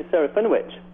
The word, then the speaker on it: Serafinowicz: Yeah, Peter Serafinowicz.